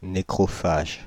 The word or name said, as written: nécrophage